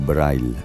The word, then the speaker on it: Breil: Joseph Karl Breil, o Breil, pronunciado a la alemana, considerado uno de los primeros profesionales de bandas sonoras.